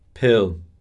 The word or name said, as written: pill